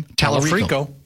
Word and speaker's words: Tallarico: So, this Senate nominee out of Texas, Democratic Senate nominee, James Tallarico. – Mm-hmm. – Is that how you pronounce his name? – Tallarico. – Yeah, I believe.